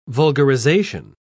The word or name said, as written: vulgarization